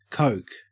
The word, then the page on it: coke: Australian English